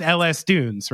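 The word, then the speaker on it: L.S. Dunes: It is pronounced LS Dunes, right? – Correct.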